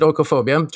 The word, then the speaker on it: oikophobia: Yeah, I would pronounce it oikophobia, just as you did.